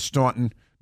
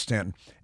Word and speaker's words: Staunton: It's spelled Staunton, but it's pronounced Staunton.